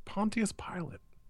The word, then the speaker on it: Pontius Pilate: How did you say his name? Pilate? – To look into the rumors that the Jewish Messiah has risen. – Pilate? Didn't you go to church? Pontius Pilate.